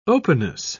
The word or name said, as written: openness